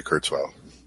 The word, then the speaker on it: Kurzweil: Did you say his name was Kurzweil? – Yeah. Hmm. Yeah, Ray Kurzweil.